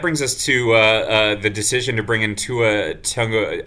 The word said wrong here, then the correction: Tagovailoa: I promised myself I would try to pronounce this right. I looked it up. It's Tua Tagovailoa. I think I got that right. Tagovailoa. There we go. And apparently the tongue is in there. So it's Tagovailoa for those listeners who don't know.